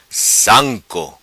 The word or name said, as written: Sanco